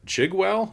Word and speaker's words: Chigwell: Chigwell, is that how you say it? – Yeah.